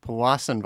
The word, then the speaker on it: Powassan: Powassan virus.